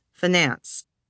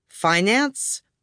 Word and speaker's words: finance: Did the word start out like fine and have the stress on the first syllable, finance? Or did it begin like fin and have the stress on the second? Finance.